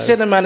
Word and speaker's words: Sinnerman: It's spelled S-I-N-N-E-R-M-A-N. – The Cinnamon. – The Cinnamon. It rhymes with Inner Man, which is kind of appropriate, really.